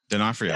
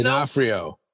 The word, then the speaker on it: D'Onofrio: It was DeFario's voice. – What was it? Dafafino? – D'Onofrio. – D'Agostino? – D'Onofrio. – D'Onofrio? Jesus Christ. What are you butchering his name for? – Okay.